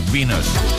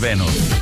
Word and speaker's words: Venus: Bueno, en inglés se pronuncia así, Venus. Aunque eh, aquí en España es Venus, clarísimamente.